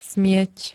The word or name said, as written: smieť